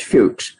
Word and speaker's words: Fuchs: Okay, and it's spelled F-U-C-H-E-S. So you have to be careful how you pronounce it, but yeah, it's Fuchs.